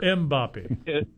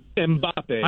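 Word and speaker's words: Mbappé: I mean, you guys know I'm a man of many trades, so the rumor has it on the Kellen of Mbappe there. You got to pronounce the M for Mbappe. – Mbappe.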